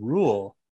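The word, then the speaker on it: Reuel: you did misspell, but it's Rule. Close enough. – How yeah, do you spell it? It's an H? – It's R-E-U-E-L.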